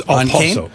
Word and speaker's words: Okposo: I think that's a silent K, huh? It's Okposo.